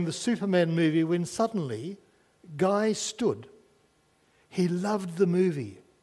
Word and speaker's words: Guy: Guy, or which is the French pronunciation, I understand, of the word guy, G-U-Y, was a five-year-old French lad.